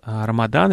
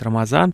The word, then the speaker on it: Рамадан: И мы обсуждаем сегодня традиции поста, который принят в исламе, это месяц Рамадан, или кто говорит Рамазан, по-разному произносится.